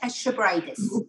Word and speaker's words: Xabregas: Yeah, Xabregas.